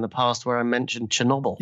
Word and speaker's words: Chernobyl: But by the way, I've discovered it's not pronounced Chernobyl, it's Chernobyl. So from now on, really? – Yeah, it's Chernobyl.